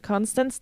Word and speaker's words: Constance: Ähm, Constanze Talbot, Constance, ah, Englisch, Constance Talbot.